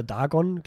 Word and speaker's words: Dagon: Oder Dagon, glaube ich, wird der actually ausgesprochen.